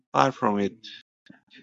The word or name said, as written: far from it